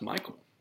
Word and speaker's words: Michael: Does he pronounce it like Michelle or something like that? – No, it's pronounced Michael.